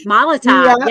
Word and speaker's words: Molotov: I can't even pronounce this bad where I'm like, oh, how do I say Molotov? – Molotov, yeah. – Okay, so anyways, let me start over there. So Debbie Burns is fire forged, a Molotov cocktail in Hello Kitty packaging.